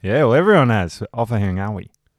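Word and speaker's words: Ofahengaue: The NGA in Maori or Pacifica is said like the NG in the English word singer. Therefore, we should not be hearing any hard G sounds, especially when you're talking about someone like Joe Offahengawi.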